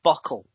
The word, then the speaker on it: bottle: the word bottle is often pronounced bockle with yeah. like a, a, couple of K's in there, um, like and hospital and uh, lots of lots of those words just just change and they become really slangy.